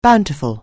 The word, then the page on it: bountiful: anglais